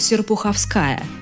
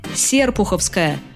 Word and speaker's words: Серпуховская: так как правильное ударение «Серпуховская» от слова «Серпухов». Инициативная группа даже собирала подписи, чтобы исправить это страшное недоразумение. Правда, ничего не вышло. Ударение на «а» не ошибочное и отражает старинную традицию, известную уже сотни лет.